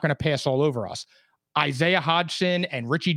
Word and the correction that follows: Hodgins: No, say his name with a little bit of respect. It's no. Isaiah Hodgins.